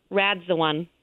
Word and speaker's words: Radzwan: Radzwan. – Radzwan. – Exactly how it's spelled. Radzwan.